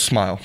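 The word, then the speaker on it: Smael: It is rhymes with smile. So, yeah, Smile Monday. It's Munden. Rhymes with London, according to that.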